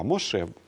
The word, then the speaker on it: Моше: Да, Моше, буква Хев, оно последнее не читается. Не читается, да.